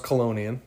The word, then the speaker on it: Chelonian: Anthony is a Chelonian chat. – Yeah, that's pronounced Colonian. The CH in Latin is a...